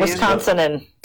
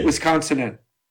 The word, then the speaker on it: Wisconsinan: How do you say Wisconsinian? – Wisconsinan. – That's the last one. Wisconsinan.